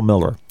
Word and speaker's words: Mueller: Yeah, well, how can a guy go from, you know, my name is Paul Miller, and it's spelled M-U-E-L-L-E-R, but allegedly pronounced Miller, and I need a better name.